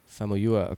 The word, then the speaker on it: Famuyiwa: Famuyiwa.